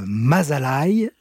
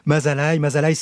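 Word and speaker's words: Mazaalai: Élise Rousseau, dans un précédent livre qui est paru aussi chez Albin Michel en 2024, l'année dernière, vous avez raconté ce voyage avec ce titre, Mazalai. Je ne sais pas si je prononce bien. – Oui, très bien. – Mazalai, c'est le nom de l'ours de Gobi, une sous-espèce de l'ours brun.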